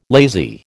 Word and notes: lazy: American English

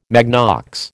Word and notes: magnox: American English

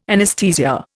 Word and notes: anaesthesia: Indian

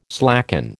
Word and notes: slacken: American English

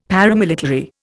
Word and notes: paramilitary: Indian

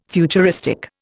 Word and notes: futuristic: Indian

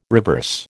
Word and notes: reverse: American English

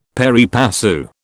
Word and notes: pari passu: British English

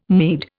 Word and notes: Mede: Indian

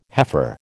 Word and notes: heifer: American English

